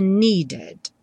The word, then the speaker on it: needed: O need é outro verbo que termina com D, need. Então, no passado, needed. Ele realmente ganha outra sílaba.